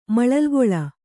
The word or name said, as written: ಮಳಲ್ಗೊಳ